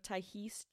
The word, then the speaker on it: Tiahease: So he gets out, and a 10-year-old girl named Tyhese Jackson. – How do you spell it? – T-I-A-H-E-A-S-E. Tyhese. Okay. That is how they said it in the documentary.